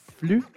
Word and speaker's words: flux: vous prononcez comment le mot qu'on écrit F-L-U-X ? – Un flux ? – Un flux, exactement. On ne prononce pas le X, on est d'accord. – Bon, moi, je pourrais te dire, si tu me dis F-L-U-X, je tombe dans le piège et je te dis, on dit flux.